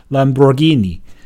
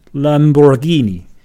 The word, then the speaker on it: Lamborghini: 在意大利里面应该读成 Lamborghini。 Lamborghini，你看我在麦克风前面，你可能看不到，因为没有图像。